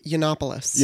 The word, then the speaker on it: Yiannopoulos: is that how you say his name? I don't care how you say his name. Since Milo... – I think it's Yiannopoulos.